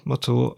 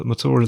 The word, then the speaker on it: Mathur: The show that you just listened to is made by me, Roy Matur, a writer. Matur is spelled M-A-T-H-U-R.